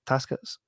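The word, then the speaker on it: Takács: The Gate, you know, Tibor Taskets? That's how you say his name.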